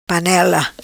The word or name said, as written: Panela